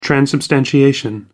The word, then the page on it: transubstantiation: American English